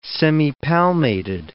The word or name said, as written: semipalmated